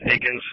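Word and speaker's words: Eakins: Pronounced Akins, spelled Ekins, Edward Adam King, Ida Nora Sam.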